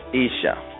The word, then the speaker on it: Isha: Rabbinic pronunciation is Isha.